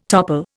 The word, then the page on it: topple: Indian